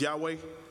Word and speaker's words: Yahweh: Well, first of all, the capital L-O-R-D, the Lord, it's translates Y-H-W-H. That's what we pronounce as Yahweh.